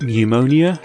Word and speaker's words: pneumonia: Number 12, pneumonia, has a silent P.